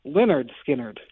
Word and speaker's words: Lynyrd Skynyrd: It's spelled to rhyme. It's because it's spelled L-Y-N-Y-R-D, Leonard Skinner. But everyone says Leonard Skinner, and I want to know if you think Leonard Skinner rhymes. – You're asking me two different questions. You're asking whether Leonard Skinner rhymes or Leonard Skinner rhymes. – Well, how do you say it? How do you pronounce the band's name? – Lynyrd Skynyrd. – Lynyrd. – Am I wrong? – Well, technically you're not, but I think most people say Lynyrd Skynyrd.